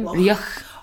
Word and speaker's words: Rioch: It's that sort of soft CH, though, that nobody can ever say at the end of my name. Rioch.